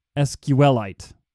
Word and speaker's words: SQLite: So it rhymes with like stalactite. And it's SQLite.